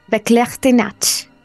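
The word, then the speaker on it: Verklärte Nacht: Beklärte Nacht.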